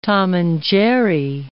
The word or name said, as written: Tom and Jerry